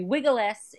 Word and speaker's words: vigiles: And the last thing you want in a flimsy, you know, tenement where, you know, chances are well, they'd be vigiles, if you, technical Latin, because you print the V is pronounced as a W, but the vigiles, who were basically like police/firefighters, aren't going to be coming to every building.